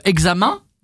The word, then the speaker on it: examen: savez, quand vous avez par exemple examen, oui. – ça s'écrit EN à la fin, donc on mm-hmm. devrait lire examen. – Mm-hmm. – Et en fait, ça se prononce main, comme si c'était une main, M-A-I-N.